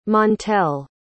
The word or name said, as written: montel